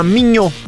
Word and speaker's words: Mignot: Non solo, c'è chi ci scrive nella valle di Gressonei, attenzione, c'è una frazione che si chiama Mignot, che si scrive con la T finale, che però è muta. – È muta, la T in francese è muta, quindi immaginati una gita.